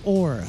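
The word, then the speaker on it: Oher: Michael Orr, I think that's how you say his name, right? – Yes. – O-A-G-R.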